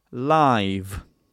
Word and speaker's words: live: So it's the voiced sound, live. The same vowel, I, but when you have a voiced sound at the end of a word, the previous vowel is actually a little bit longer.